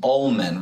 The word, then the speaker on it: Ollmann: Ollmann.